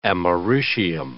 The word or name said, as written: americium